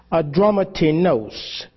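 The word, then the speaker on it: Adramyttenos: And entering into a ship at Adramatinos, that's how that word is pronounced. I had to get my bearings together on it. Adramatinos. It's not spelled the way it sounds. But when you go and you actually hear how the word is spelled or how the word is pronounced in the Greek, it's Adramatinos.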